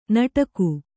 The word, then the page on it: ನಟಕು: kannada